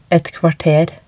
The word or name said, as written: et kvarter